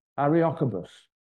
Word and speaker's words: Areopagus: But when Paul was in Athens, he preached to the crowd at what they call the Areopagus, that's the right way to say it, which was an area where the Greeks met together to discuss various items.